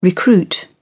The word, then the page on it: recruit: British English